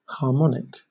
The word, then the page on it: harmonic: British English